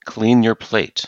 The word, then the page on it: clean your plate: idiom